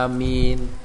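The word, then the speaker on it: amin (آمين): Jadi yang penting bacanya amin. Mimnya enggak boleh ditasdid nggih. Kalau ditasdid nanti artinya sudah beda.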